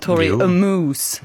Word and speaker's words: Tori Amos: Hon skrev på sin Instagram och ville skoja lite om Tori Amos Moose på sin Instagram. Jag vet inte om jag uttalar det rätt. Hur hade du sagt? Tori Amos. – Jo, det tror jag man kan säga.